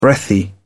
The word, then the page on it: breathy: British English